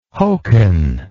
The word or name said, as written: hokan